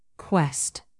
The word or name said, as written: quest